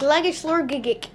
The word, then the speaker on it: Glagishlorgigik: And I've just come across a very interesting creature called the Glagash Lagorgigik. – Yes. – Is that how you pronounce that? – Glagishlorgigik, yes.